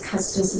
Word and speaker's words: Custis: The Padilla court talks about, I think it's Custis is how you pronounce it, C-U-S-T-I-S, and talks about the only place that the U.S.